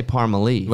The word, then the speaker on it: Parmalee: Listen, I may be saying it wrong. I say Parmalee. – Yeah, you're right. You say Parmalee. – Well, Parmalee, yeah. Like Pamela Lee.